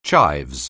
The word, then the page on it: chives: anglais